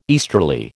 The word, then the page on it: easterly: American English